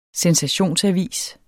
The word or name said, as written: sensationsavis